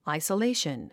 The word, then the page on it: isolation: àisəléiʃən; アイソレイション